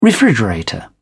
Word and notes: refrigerator: British English

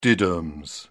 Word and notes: diddums: British English

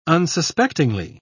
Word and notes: unsuspectingly: British English

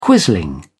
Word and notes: quisling: British English

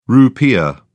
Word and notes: rupiah: British English

